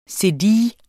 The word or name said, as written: cedille